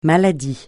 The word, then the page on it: maladie: francais